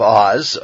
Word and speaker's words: Uz: The best guess for the land of Uz, of course in English it would be the land of Oz, we'll just stick with the Hebrew translation, which the Hebrew the way to pronounce it, which is Uz.